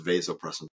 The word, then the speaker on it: vasopressin: Now, some people pronounce it vasopressin, but proper pronunciation is vasopressin.